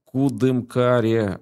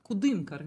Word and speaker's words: Кудымкар: Вот как-то вот мы так в Кудымкаре. А правильно, пожалуйста, ударяйте. Как правильно произнести? Ты можешь произнести правильно? Ударение на «ы». – Кудымкар, Кудымкар, видишь, на «ы».